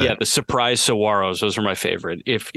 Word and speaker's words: Saguaros: And the G is silent. The Surprise Saguaros. Saguaros, Saguaros, the Cactus.